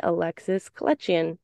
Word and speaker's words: Alexis Kletjian: And today on the podcast, we are joined by Alexis Kletjian.